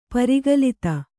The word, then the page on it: ಪರಿಕಲಿತ: kannada